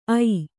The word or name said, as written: ಐ